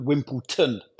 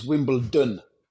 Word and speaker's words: Wimbledon: They call it Wimbledon, pronouncing it with a T. It's a D. It's Wimbledon.